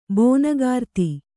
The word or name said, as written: ಬೋನಗಾರ್ತಿ